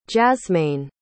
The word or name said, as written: jazmaine